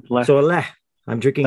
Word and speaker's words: Lech: Is it pronounced L-E-C-H? How's that one pronounced? – So it's, for some reason, C-H and H are almost the same in Polish. So you just pronounce that as if there was no C in there. So lek.